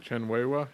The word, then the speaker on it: Chen Weihua: Chen Wohua. Why you? Why? – How do Chen, you say his name? – Chen Weihua.